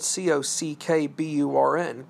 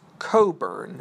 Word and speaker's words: Cockburn: Now, if you pronounce his name, it's spelled C O C K B U R N Cockburn, but to the British, it is Coburn.